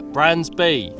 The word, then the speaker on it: Bransby: Bransby.